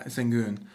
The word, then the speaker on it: Sengun: Yeah, Sengun.